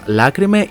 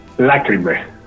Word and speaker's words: Lacrimae: Ε, ποια είναι η σωστή προφορά του ονόματο τη μπάντα, Λάκρυμε ή Λακρυμέ Λάκρυμε Λάκρυμε Με τόνο στο, ναι, ναι, στο πρώτο αλφα.